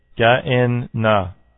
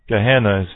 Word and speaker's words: Gehenna: It's also this Greek word is pronounced Ge-en-na. Ga-en-na. Gehenna is what I believe it's pronounced.